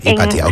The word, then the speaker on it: Hypatia: Además, tu participación en la misión, ¿cómo se dice? Hypatia. – Hypatia, ok.